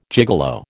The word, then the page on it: gigolo: American English